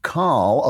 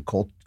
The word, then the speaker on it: Coll: Well, according to Steve Coll of The New Yorker, I guess that's how you pronounce it, maybe Coll, C-O-L-L, of The New Yorker, the problem is there's too much freedom of speech.